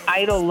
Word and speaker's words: EIDL: But I want to go back to the first loan that we were talking about, the Economic Injury Disaster Loan, or EDL. Wait, we pronounced it EIDL. My bad. I'm giving it totally new initials, Rebecca.